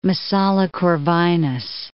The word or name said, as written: Messala